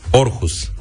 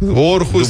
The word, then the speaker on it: Aarhus: Aarhus. – Orhus. – Mă scuzați. Orhus scris cu a dublă.